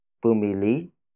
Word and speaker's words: bumili: Bumili si Kuya Rem ng laruan para sa anak niya.